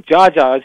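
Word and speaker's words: Geagea: This is very significant development because Samir Jaja, as he's pronounced in Lebanon, was a head of a Christian militia over there.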